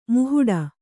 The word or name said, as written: ಮಹಡಿ